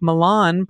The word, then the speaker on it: Milan: Indiana also names of great European cultural centers like Milan, pronounced with a very long I sound.